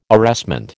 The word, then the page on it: arrestment: American English